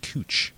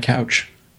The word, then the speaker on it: Couch: They also have a street that's spelled couch but pronounced cooch. – Yep. – Which is funny. – But they let people pass with that calling that one couch.